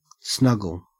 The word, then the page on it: snuggle: Australian English